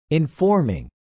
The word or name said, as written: informing